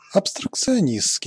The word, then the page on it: abstrait: France; [ap.stʁɛ]